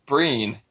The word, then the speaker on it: Brienne: Well, first off, going with Brienne, is that how you always pronounced her name, reading the books, Bags? I always thought it was Breen. – When I pronounced her name, I thought it was Brienne.